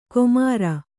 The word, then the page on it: ಕೊಮಾರ: komāra